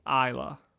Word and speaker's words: Aella: A-Y-L-A? – A-E-L-L-A. But it's pronounced Isla.